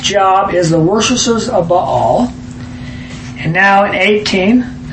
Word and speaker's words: Baal: So think of two syllables, Baal.